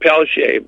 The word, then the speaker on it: Peltier: Well, and we pronounce it Peltier, but it's okay.